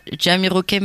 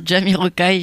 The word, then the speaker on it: Jamiroquai: Et je rectifie, c'est pas Jamiroquai, mais bien Jamiroquai. J'avais mal prononcé tout à l'heure.